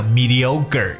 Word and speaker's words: mediocre: 大家有听到好像后面这个 c r e 发成 g 的发音，其实它的音标中注的是 k。 mediocre，只不过在读的时候呢，很容易听成 mediocre 这样的感觉，mediocre。